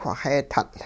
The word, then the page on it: whixee-tutL': hupa